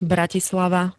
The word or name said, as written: bratislava